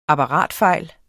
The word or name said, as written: apparatfejl